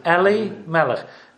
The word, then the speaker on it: Elimelech: Yeah, Elimelech.